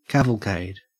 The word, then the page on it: cavalcade: Australian English